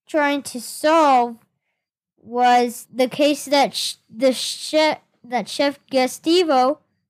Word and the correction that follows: Gesto: Did I say that right? – Chef Gesto. – Yes.